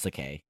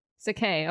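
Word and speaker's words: Sacae: By the way, it's pronounced Sakai. – Sakai, okay.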